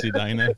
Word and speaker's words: Edina: Adina. – Edina. – That's Adina. – No, it's Edina. – It's pronounced Edina.